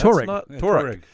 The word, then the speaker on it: Touareg: It's the Touareg concept truck. Look at that. That's Touareg.